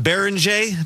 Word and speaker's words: Berenguer: They got the young guy, I'm going to butcher his name, Johan Berenguer, I believe it is. – Good job. – Not to be confused with 1980s Twins reliever Juan Berenguer. Similar spelling, different pronunciation.